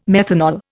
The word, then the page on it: methanol: Indian